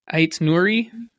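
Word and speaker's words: Aït-Nouri: It's Ryan Aitnouri.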